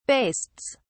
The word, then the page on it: bastes: British English